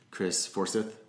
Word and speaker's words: Forsyth: You've had some great artists on lately: Garcia's Peoples, Chris Forsyth. – I said Forsythe. – Yeah, I didn't ask him. – Absolutely, yeah. He didn't stop you though, did he? – No, I didn't. – I've said it wrong.